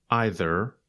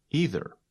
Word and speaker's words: either: We can pronounce it either or either. – You say either, and I say either.